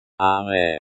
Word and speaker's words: Arrêt: Arrêt. – This is the verb, to stop, in the imperative mood, which is used to give commands. – The. – At the end of the word is silent.